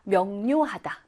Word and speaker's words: 명료하다: 자, 우리 명료, 이거를 어떻게 읽냐면, 명료라고 소리 내어서 읽어야 해요. 명료! 막 이렇게 읽지 않고요. 명료하다, 명료하다. 그러니까 편하게 발음을 하다 보니까 이 니은처럼 소리가 나게 되어 있습니다.